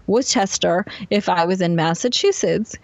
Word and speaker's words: Worcester: Was how you spell, you pronounce Worcester, which is spelt W-R-C-H-E-S-T-R, like Worcester, but we say it W-O-O-S-T-E-R, like rooster, Worcester.